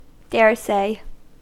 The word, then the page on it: daresay: American English